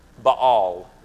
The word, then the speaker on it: Baal: He says, "You will call me my husband, and no longer will you call me my Baal," is the pronunciation, but in America we say Baal, so and it's easier.